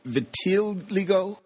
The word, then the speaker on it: Vitiligo: and I think it was vitiligo.